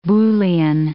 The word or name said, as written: Boolean